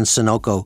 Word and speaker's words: Sonoco: Brand twins can exist phonetically too. For example, there is Sunoco and Sonoco. Sunoco, spelled with a U, is in the petroleum business, and Sonoco, spelled with an O, is in the consumer and industrial packaging business. Then there is also Sysco and Cisco.